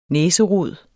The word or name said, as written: næserod